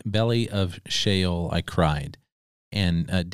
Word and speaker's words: Sheol: Sheol.